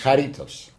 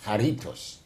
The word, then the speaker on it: Charitos: Charitos. – Charitos. – Charitos. Also die Betonung kommt auf das I, Charitos.